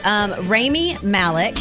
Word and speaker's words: Rami Malek: Rami Malek.